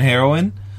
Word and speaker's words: heroine: It could be my southernism, but yeah, it's heroin and heroin. The drug doesn't have an E at the end, and yes.